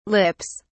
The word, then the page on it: lips: British English